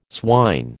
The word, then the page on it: swine: American English